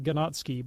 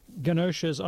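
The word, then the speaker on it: Ganoczy: It looks like Ganotsky, but I think it's pronounced Ganoche.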